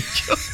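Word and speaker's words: Jobe: No, Jude y Job. Se escribe Jove, pues.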